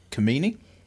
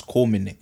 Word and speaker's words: Komene: I actually got a funny story because when I was at school, I don't know if you find this funny, but the teacher used to mispronounce my last name. She used to say Komene. I was like, it's not Komene, it's Komene.